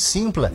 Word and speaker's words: Sympla: Lembrando que Simpla se escreve com S-Y-M-P-L-A.